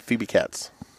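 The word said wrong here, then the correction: Phoebe Cates: Kates. – Okay, there you go.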